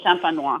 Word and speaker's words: champenoise: You mentioned fromage d'Auvergne next, I think, with champenoise, whatever that's pronounced. – Method champenoise. – Yeah. So you call it an absolute crowd pleaser, the fromage d'Auvergne.